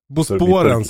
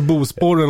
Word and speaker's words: Bosporen: Bosporen säger jag, men, men det är... Ja, ja Bosporen, det, det är nog rätt, mer rätt ja. uttal. – Alltså Bospåren låter mer som ett ställe i Värmland.